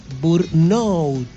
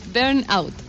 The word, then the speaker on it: burnout: También está el síndrome de la cabeza quemada o síndrome de burnout. Dicen así, no sé cómo se pronuncia. – Burnout. – ¿Cómo es? – Burnout. – Ah, burnout.